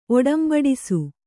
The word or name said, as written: ಒಡಂಬಡಿಸು